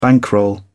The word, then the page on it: bankroll: British English